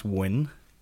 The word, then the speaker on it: Nguyen: And Sean's like, yeah, it's pronounced Win.